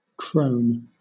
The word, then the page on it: crone: British English